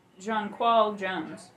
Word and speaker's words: Jonquel: Also, I realized we were pronouncing that woman's name wrong. It's Jonquel Jones.